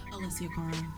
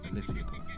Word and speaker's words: Alessia Cara: Alexa Carr or Ella May? – Alessia Cara. – Yeah, I said it wrong, right? Alessia Cara.